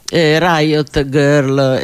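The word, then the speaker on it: Riot Grrrl: è un grr, quello, diciamo così, un ruggito che mima sulla, anche sulla parola girl, Riot Girl, eccetera.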